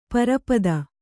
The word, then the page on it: ಪರಿಪೀತ: pari pīṭha